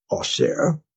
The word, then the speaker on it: Auxerre: What is Auxerre? Auxerre, right? So that is the French pronunciation.